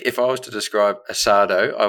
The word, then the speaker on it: asado: I don't think Michael was there, but Jason was there, a couple other boys, his brother, and we had asada. – Asado. – Is that how you pronounce it? – Asado. – Okay, so I'll pronounce it Australian way, asada.